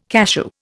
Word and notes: cachou: Indian

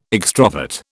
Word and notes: extrovert: British English

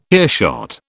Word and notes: earshot: British English

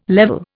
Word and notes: level: Indian

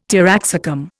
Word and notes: taraxacum: Indian

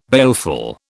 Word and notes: baleful: British English

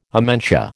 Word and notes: amentia: American English

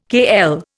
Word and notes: kl: Indian

